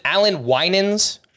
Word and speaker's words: Winans: Is it Alan Winans? – It is.